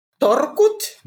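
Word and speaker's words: talkoot: um talkut did i say that right Torkut? There you go. That sounds much better than what I just said.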